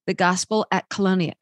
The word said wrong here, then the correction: Colonus: How do you pronounce that? – Colonus. – Okay, I was close. The Gospel at Colonus.